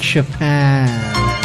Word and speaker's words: Chopin: The correct pronunciation is I like Chopin.